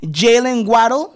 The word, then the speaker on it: Jalen Waddle: Jalen Waddle, ¿lo pronuncié bien? Sí, sí, sí.